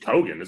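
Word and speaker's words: Keoghan: It's just Kogan, isn't it? – Is it Kogan? Okay. The K-E-O-G thing really bothers me, but either way.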